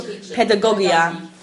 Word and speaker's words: pedagogia: He says in Hebrew, pedagogia. Pedagogia, right? That's the pronunciation.